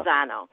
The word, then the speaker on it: Lozano: Lozano? – Yeah. Lozano.